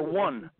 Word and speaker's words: won: Or one. WON. There's not many ways you can pronounce this.